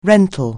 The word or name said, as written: rental